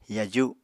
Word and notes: yaju: apatani

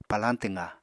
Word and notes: palañ twqá: Apatani

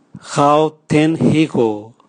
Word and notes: gaotenhigo: Raga

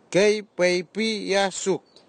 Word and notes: keipeipwiyasuk: Nafe; Tanna

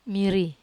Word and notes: mari: Wamesa

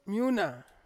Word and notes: miuna: Ansus

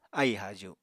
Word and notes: aì-haaju: apatani

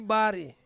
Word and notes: bari: Ansus

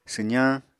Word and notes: swzañ: apatani

